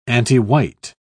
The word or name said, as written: antiwhite